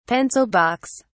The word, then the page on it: pencil box: British English